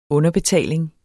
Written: underbetaling

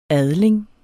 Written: adling

